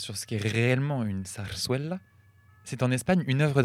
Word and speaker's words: zarzuela: Alors, je tiens à dire d'abord que nous avons toutes mal prononcé parce que les deux Z se prononcent S et le U se prononce ouais. Donc on dirait plutôt zarzuela.